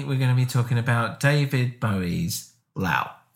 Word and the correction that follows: Low: I think it's I think it's pronounced low. Low. Low. Okay. Like the noise a cow makes.